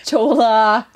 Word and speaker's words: cholla: It's spelled C-H-O-L-L-A. And I have said it several different ways. – Cholla. – Cholla. Cholla. – Today she was like, Acholla. – Acholla. – Acholla. – So it is pronounced Cholla.